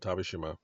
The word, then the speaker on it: Tavershima: And Tavishima, my name, is spelled T-A-V-E-R-S-H-I-M-A.